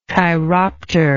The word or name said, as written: chiropter